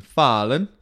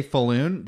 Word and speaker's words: Falun: Yeah, if you say Falun, they'll laugh at you, which is what I was saying for most of the time. Speaking Swedish is almost all about cadence.